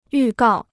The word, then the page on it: 预告: yù gào